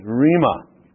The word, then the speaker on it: rhema: But 5 times it is the Greek word rhema, and I'll just spell that here, R-H-E-M-A, rhema, the rhema of God.